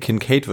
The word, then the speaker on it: Kincaid: Ähm, Kincaid, Kincaid wird da, glaube ich, ausgesprochen.